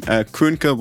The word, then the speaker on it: Köhnke: Yeah, the prename was perfect, the first name. Kernke would be the second one, but it was really, really good for an English speaker.